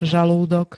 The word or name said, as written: žalúdok